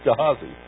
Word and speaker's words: Gehazi: Everyone pronounces it different, but I'm preaching today, so it's Gehazi.